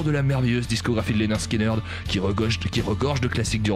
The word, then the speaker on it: Lynyrd Skynyrd: Lynyrd Skynyrd, comprenez, ça se prononce Lynyrd Skynyrd, puisqu'en lisant le nom du groupe, personne n'est capable de le prononcer correctement, n'est-ce pas Alain.